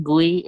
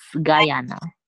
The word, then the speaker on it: Guyana: Okay, so it's not Gui, it's Guyana.